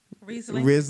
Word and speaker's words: Riesling: Nivol Moscato, which is a wrestling. Is that Riesling? How you pronounce it? – Riesling.